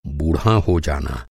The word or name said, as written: बुढ़ाना